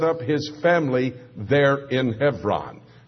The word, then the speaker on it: Hebron: What is so ironic about that is that Hebron, and maybe you pronounce it Hebron, H-E-B-R-O-N, but in Hebrew the V sound is given to the B, in Hebron,